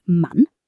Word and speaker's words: Mann: Take the letter A. In German, a short A sounds like the A in cat. So the word Mann, meaning man, is pronounced with that short, crisp A sound.